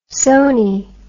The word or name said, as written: sony